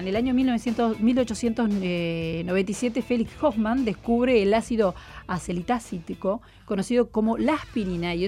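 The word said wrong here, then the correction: acetilsalicílico: Es acetil salicílico. – Sí, lo dije mal, ¿vio?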